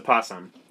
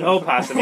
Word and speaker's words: opossum: Yeah, I did learn that's actually like the real pronunciation of it, too. – Is it really? – Yeah, so straighten out opossum, right? Uh-huh. But like, for whatever reason, like, we just decided to redact the O and we just call it opossum.